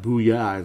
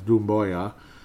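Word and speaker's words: Doumbouya: It's not Booyah, it's Dumboya.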